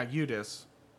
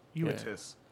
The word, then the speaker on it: Eudes: Or, am I saying that right? – Yeah, Eudes. – You like? – Eudes. – Yeah, there we go.